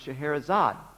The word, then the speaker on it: Scheherazade: And then she told me that her name could also be pronounced Scheherazade. Ah, Scheherazade, I knew.